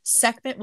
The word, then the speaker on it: Sekhmet: Sekhmet was associated with plagues and pestilential diseases. Did I say that right? – Yeah.